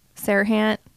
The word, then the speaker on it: Serhant: The same thing with, I always say his name wrong. Ryan Serhant. – Serhant.